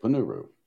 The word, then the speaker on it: Ponnuru: Yep, Ponnuru. At least that's the way he pronounces his name.